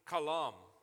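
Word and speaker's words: kalam (חלם): In the Hebrew, the word had a dream is pronounced this way, kalam.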